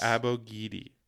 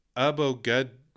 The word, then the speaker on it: Abogidi: So Effa Abogidi. Effa, yes. Abogidi. – Abogidi. – Abogidi. – Yes. – So that's how you say his name. That's how he says his name. So that is how his name is said.